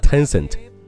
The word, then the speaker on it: Tencent: 你可千万不要把这个英文单词当做拼音来去拼，就读成了 Tencent。正确的读法再来一遍，Tencent。 Ten 是一个 n 的音，cent 也是一个 n 的音，嘴巴要稍微的张大一些。